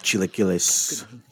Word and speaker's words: chilaquiles: Oh, I fucking love chilaquiles. Love it. Did I say it right? – Yeah, yeah, you got it.